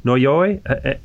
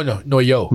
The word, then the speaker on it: noyaux: Noya, Noyoy. You have French in your background. N-O-Y-A-U-X. It's Noyo. – The name. – No, Noyo. – Noyo.